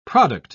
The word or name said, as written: product